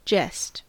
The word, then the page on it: jest: American English